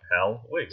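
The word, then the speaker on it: Hellwig: Oh, so it is literally like Helwig.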